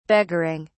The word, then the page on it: beggaring: British English